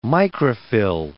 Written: microphyll

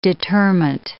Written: determent

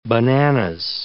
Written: bananas

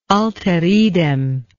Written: alteridem

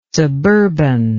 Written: suburban

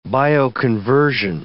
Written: bioconversion

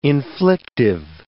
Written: inflictive

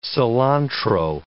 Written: cilantro

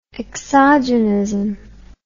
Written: exogenism

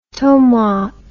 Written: tomate

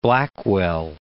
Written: Blackwell

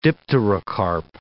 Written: dipterocarp

